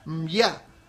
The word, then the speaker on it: Mbiá: Es una aldea que pertenece a la comunidad Mbiá, guaraní, creo que se pronuncia así, porque son tres consonantes continuas, M, B, larga, Y y A, Mbiá,